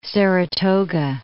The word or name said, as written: Saratoga